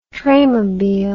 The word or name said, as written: traymobile